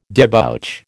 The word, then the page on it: debouch: American English